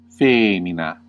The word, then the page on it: woman: /ˈwʊm.ən/